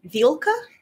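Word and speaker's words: Vilka (вилка): You know, in Russian there's a word that sounds like Velka. – Is there? – Yeah, it's pronounced Vilka, like an E instead of an E. And it means fork.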